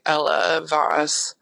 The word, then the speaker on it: Ella Vos: I always expected that I would go by Ella Voss, pronounce it like a first and last name like that.